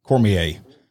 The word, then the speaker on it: Cormier: Is it pronounced Cormier? – Cormier.